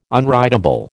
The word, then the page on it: unwritable: American English